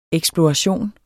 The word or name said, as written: eksploration